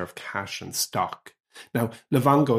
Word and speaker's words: Livongo: Isn't that the right pronunciation? – Yeah, Livongo.